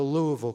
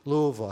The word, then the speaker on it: Louisville: And it spread up across to Buffalo, over to Chicago, across to Newark, New Jersey, over to Louisville, Kentucky. Louisville, I had to learn how to pronounce that word. It's not Louisville. It's Louisville. Louisville.